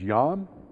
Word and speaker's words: Yam: And he does this because the Hebrew word for sea, excuse my Hebrew pronunciation, is Yam, Y-A-M.